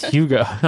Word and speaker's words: Hygge: Every time I say I'm like Hygge. And then you're like, Hygge. No, it's Hygge. I'm like, I'm sorry, I'm not cultured.